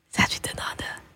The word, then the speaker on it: Sachidananda: That's how you say it. Sachidananda.